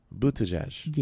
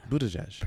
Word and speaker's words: Buttigieg: I did not get this, but presidential candidate Buttigieg. I think I said this right. Buttigieg. – I have no idea. – I've practiced his last name for so long. – It's Buttigieg. – Yeah. – Buttigieg.